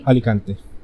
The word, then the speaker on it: Alicante: Alicante, Spain. – Spain, perfect. – Did I say that right? Do you know of that city? – Alicante.